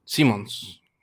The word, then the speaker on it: Simmons: J.K. Simmons. Simmons. Sí, es que estaba usando Simpson. No, no, Simmons.